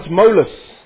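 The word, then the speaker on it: Tmolus: Mount Molus, and you spell it with a T, by the way.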